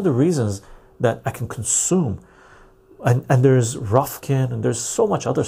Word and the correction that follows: Rokfin: I get notifications from Odysee, I get notifications from Ruffkin, I get oh, or not Rofkin, uh, Rokfin. I pronounce it.